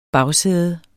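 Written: BAGSÆDER

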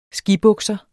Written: skibukser

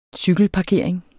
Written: cykelparkering

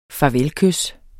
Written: farvelkys

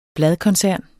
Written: bladkoncern

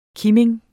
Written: KIMINGEN

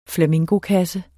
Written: flamingokasse

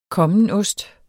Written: kommenost

